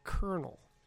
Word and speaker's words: colonel: And speaking of pronunciation, why is C-O-L-O-N-E-L pronounced kernel?